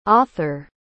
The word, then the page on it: author: English